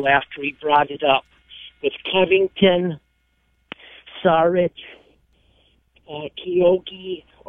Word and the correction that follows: Okogie: I'm sorry, I probably said that wrong. – Okogie.